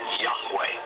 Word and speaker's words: Yahweh: Today, Yud, Hey, Wah, Hey is most often rendered as Yahweh when written or spoken by Christians and others. It is still never pronounced aloud by Orthodox Jews. The pronunciation Jehovah is an older, less accurate rendition of these letters.